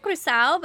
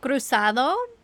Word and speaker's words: Cruzado: All right, then we have what you talked about, which is your tradition, Espiritismo We pronounce it Cruzao, but sometimes Cruzao. It's Cruzado.